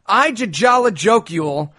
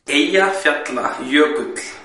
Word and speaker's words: Eyjafjallajökull: I'm going to go with Leachy, who donated $100 just to hear you roast an Icelandic volcano spelled E-Y-J-A-F-J-A-L-L-A-J-O with an umlaut, K-U-L-L. – Okay. This fucking thing is spelled Ijajalajokul, but it's apparently pronounced Eyjafjallajökull.